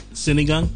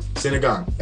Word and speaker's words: Sinigang: Um, I think it's called uh, singang. – Sinigang. – Sinigang. Ah, yeah.